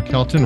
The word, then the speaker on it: Kelton: With Aaron Kelton, rhymes with Elton.